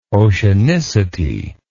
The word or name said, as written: oceanicity